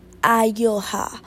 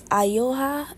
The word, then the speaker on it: Ayoa: So the platform name is Ayoha, so it's spelled AYOA. Okay, let me say it again, it's Ayoha, it's AYOA. It is beautifully simple,